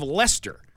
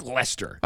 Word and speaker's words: Leicester: Look at the town of Leicester. Right. Doesn't even look anything like Leicester.